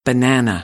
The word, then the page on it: banana: American English; æ